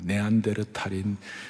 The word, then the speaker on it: 네안데르탈인: 제가 중학교 다닐 때 중2, 중3 때생물 시간에 뭘 배우는가 하면 우리는 네안다르 네, 발음 네안데르탈인 크로만용인 자바원인 다 원숭이들 아니에요, 다들? – 네?